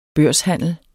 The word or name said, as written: børshandler